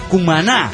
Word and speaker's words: Cumaná: Cumaná se pronuncia Cumaná. Seguro no acentuó quien envió este mail.